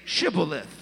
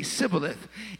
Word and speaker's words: Shibboleth: And they'd say, "Well, then say Shibboleth." And they couldn't pronounce the H. So they would say Sibboleth.